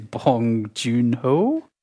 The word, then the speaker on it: Bong Joon-ho: I think his name is Bong Joon-ho. – So I'm going to say Bong Joon-ho.